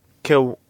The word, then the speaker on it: Kiwanuka: His name is pronounced Michael Kiwanuka.